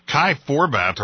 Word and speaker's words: Kai Forbath: And how about this kicker, this kid, Kai Forbath, or whatever his name is? – That's right. You know, you pronounced it correctly.